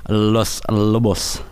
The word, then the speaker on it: Los Lobos: How do you pronounce that? – Los Lobos.